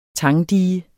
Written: tangdige

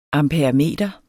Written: amperemeter

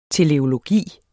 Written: teleologi

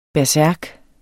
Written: bersærk

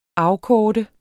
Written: afkorte

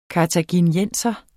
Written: kartaginienser

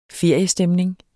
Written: feriestemning

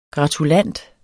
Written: gratulant

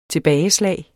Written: tilbageslag

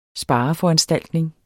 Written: spareforanstaltning

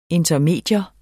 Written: intermedium

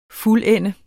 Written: fuldende

